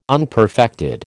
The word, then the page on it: unperfected: American English